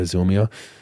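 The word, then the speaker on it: Zillmere: Zilmia? Is that how you pronounce it? Yeah, Zilmia. So check that out if you want to watch some women's hoops up here before the season officially starts.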